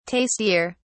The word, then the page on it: tastier: British English